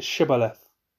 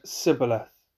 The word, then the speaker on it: Shibboleth: When he said no, they said to him, "Then say Shibboleth." And he said Sibboleth, for he could not pronounce it right.